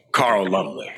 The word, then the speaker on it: Carl Lumbly: There's a character in the show who was a super soldier, a black super soldier who is played by Carl Lumbly. Am I pronouncing his name correctly? – The great Carl Lumbly.